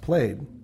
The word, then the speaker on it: plaid: The word plaid is actually an old Scots word that's supposed to be pronounced plaid, which actually literally means blanket.